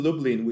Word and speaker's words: Lublin: Currently, I'm in Lublin, which is a city that's pronounced strangely close to Dublin, Ireland, but it's nowhere close.